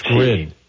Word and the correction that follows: Sanskrit: Not with a D in it, so yeah, I apologize for not pronouncing it correctly.